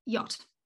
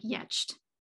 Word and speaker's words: yacht: So say the word is yacht, you decode it and you come up with something like yetched.